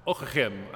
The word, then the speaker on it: Ockeghem: Despite its very Flemish sound, Ockeghem, I'm told is how it's supposed to be pronounced, he was almost certainly Francophone.